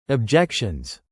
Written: objections

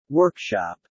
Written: workshop

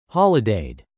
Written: holidayed

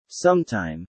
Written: sometime

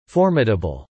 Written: formidable

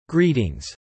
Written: greetings

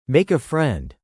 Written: make a friend

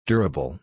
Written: durable

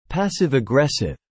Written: passive-aggressive

